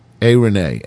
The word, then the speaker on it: Aarone: The 60-count indictment against Aaron Thompson paints a grim picture of brutality against his daughter, Arone, her name is pronounced, A-A-R-O-N-E, Arone, and seven other children in his suburban Aurora, Colorado home.